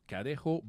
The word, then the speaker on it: cadejo: Et c'est, si tu vois le fameux, je vais le prononcer comme il faut, cadejo blanco, ou el cadejo negro, tu as le blanc et le noir.